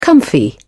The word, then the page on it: comfy: British English